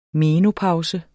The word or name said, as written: menopause